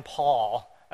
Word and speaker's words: Paauw: This guy, Glenn Paul, as he pronounces his name, which it's a weird spelling there, P-A-A-U-W, Glenn Paul.